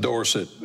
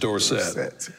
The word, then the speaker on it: Dorsett: And so you were like, guys, my name is not Dorsett. My name is Dorsett.